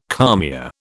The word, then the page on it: kalmia: British English